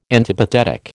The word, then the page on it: antipathetic: American English